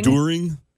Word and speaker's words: during: Somebody's pointing out the way that I say during. – Daring? – During? – You tend to say daring. – During. – Yep. – Yep. I know that I mispronounced that word.